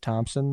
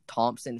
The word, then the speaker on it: Thompson: It's Thompson, but my family came from a Danish, you know, like background. So we pronounce everything with an "a." You know, it's like it's not Thompson. It's Thompson.